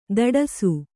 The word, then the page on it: ತಡಸು: taḍasu